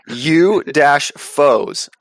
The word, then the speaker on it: U-Foes: They fight the UFOs. I think that's how you say it. – The UFOs? – U-foes. F-O-E.